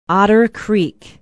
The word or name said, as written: otter creek